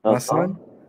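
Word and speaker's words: Maussan: Masan, Hyman Masan, but yeah, he's known in Mexico.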